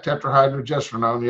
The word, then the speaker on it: tetrahydrogestrinone: You teamed up with a bodybuilding chemist, and you guys created, let me see if I can say this, the short word is the clear, tetrahydrogestrinone. – Pretty good, tetrahydrogestrinone, yes.